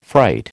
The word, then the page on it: fright: American English